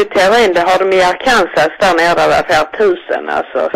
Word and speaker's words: Arkansas: Den delstaten, det stavas så som du uttalade, men det uttalas Arkansas. – Jaha, det visste jag inte. – Nej, ja, det heter Kansas, Kansas, men Arkansas.